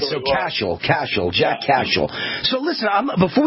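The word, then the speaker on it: Cashill: And it's Cashel, by the way. Rhymes with Dashiell, our former...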